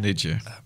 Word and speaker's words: Berninger: It's Matt Berninger.